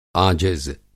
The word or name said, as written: आजिज़